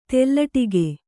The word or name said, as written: ತೆಲ್ಲಟಿಗೆ